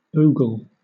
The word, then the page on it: ogle: British English